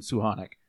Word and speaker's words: Suchanek: You may have heard his name pronounced as Suchanek in the past, or Suchanek, Tomas Suchanek, or Suchanek. He did say in North America, most people call him Suchanek.